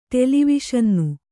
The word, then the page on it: ಟೆಲಿವಿಷನ್ನು: ṭeli viṣannu